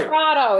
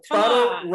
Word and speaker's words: Crotto: Crotto, sorry. – Crotto, it's Crotto with Lotto. – Crotto rhymes with Lotto.